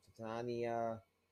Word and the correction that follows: Tatiana: Yeah, Tatiana Maslany.